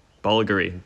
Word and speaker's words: Bulgari: Bulgari. The V is just a stylized U.